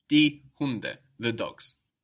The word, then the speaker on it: Hunde: The dogs is die Hunde. – Die Hunde. – And we notice also that the D is soft again, because it's not on the end of the syllable like in der Hund.